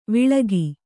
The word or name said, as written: ವಳಕು